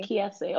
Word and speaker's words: Kiese: Is it Kies? – Kiese. – Okay, so it kind of sounds like my name.